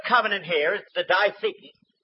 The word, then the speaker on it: diatheke: The word here for covenant is a word called diatheke, D-I-A-T-H-E-K-E, long E.